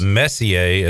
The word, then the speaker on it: Messier: Now you have, I believe, I'm going to pronounce this Messier, as in Mark Messier. It could be Messier, but I believe it's Messier.